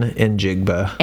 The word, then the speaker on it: Njigba: like shocking, but it is Jackson Smith Njigba. Did I say that right? – N as in E-N, Njigba.